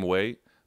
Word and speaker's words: Weah: Tim Weah. Tim Way. W e h h w e a h.